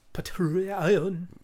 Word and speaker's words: Patreon: Patreon. Kind of fun. God damn it. Can't even say it right. – Patreon, sorry. – Patreon. – The first one makes the middle one have emphasis.